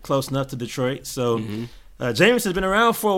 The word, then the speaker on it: JMSN: So my pick for this week is actually going to be Jameson. His name is spelled J-M-S-N.